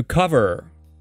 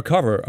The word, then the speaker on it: cover: Often it's pronounced as cover, cove. Now, cove is a different thing. Cover, it's a short a sound rather than o.